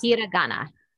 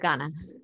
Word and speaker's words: hiragana: Hirangi, I maybe pronounced hiragana. That. Hiragana. – Hiragana, yes, there we go.